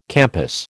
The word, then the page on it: campus: American English